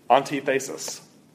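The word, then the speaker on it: Antithesis (ἀντίθεσις): Here's how you say it in Greek. Antithesis. You recognize that?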